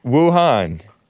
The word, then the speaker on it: Wuhan: Wuhan.